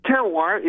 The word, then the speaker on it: terroir: if I'm pronouncing this right, but it's terroir, right? – Terroir, perfect pronunciation.